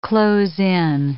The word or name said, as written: close in